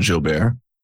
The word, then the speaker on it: Jilbert: As Andrew correctly said, my name is Collin Jilbert. If you ever pronounce it Jilbert, don't worry about that.